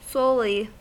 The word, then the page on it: solely: American English